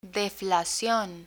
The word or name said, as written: deflacion